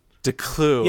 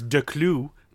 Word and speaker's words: Declou: Declou. Yeah, okay. – Technically it's Declou. But I don't expect anybody to do the French pronunciation, so just a clue is fine.